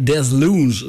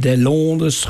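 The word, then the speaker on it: Deslondes: Zo spreek je het uit. Ik heb het even opgezocht. Ik sta altijd te zoeken van hoe spreek je dat nou in vredesnaam uit? Desloons, groeit het op de Franse toer? Nee, Desloons.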